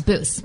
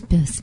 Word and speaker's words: Buss: So today we have on our podcast, after we recorded with you, we have David Boos or Buss. I'm not sure how you pronounce it. – It's Boos. – Boos.